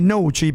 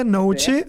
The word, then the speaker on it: Noci: Faccio un salto, 9 luglio Noci, provincia di Bari, quindi Noci per dirla con la pronuncia pugliese, Noci, sì.